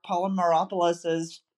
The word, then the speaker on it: Polymeropoulos: It's Marc Polymeropoulos.